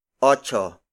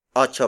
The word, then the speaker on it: otso: Ocho. Ocho is spelled as O-T-S-O.